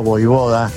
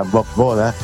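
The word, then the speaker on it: Vojvoda: Mauricio Pasadores de Victoria Entre Ríos, saludo para vos y equipo, muy contento con la actualidad de Racing, lo veo a Voivoda, que así se debe pronunciar, no como la mayoría lo pronuncia Bob Boda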